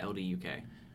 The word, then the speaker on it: LDUK: Luddock Music, I guess? – No, I think it's all capital letters, so you pronounce it. LDUK? – Yeah, LDUK.